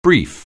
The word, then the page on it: brief: anglais